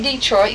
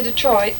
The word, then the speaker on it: Detroit: And you have to say Detroit. You can't say Detroit. – Mm-hmm. But it's put on the first syllable.